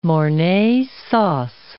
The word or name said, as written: Mornay sauce